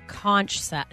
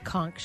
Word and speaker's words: conch: A conch shell, or a conch shell, depending on how you want to pronounce it. Either pronunciation is correct.